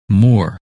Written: Moore

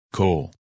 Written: Cole

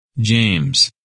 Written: James

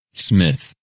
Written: Smith

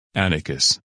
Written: Atticus

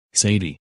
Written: Sadie

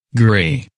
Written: Gray

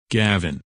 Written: Gavin

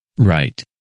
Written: Wright

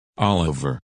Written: Oliver

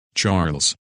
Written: Charles

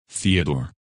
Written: Theodore

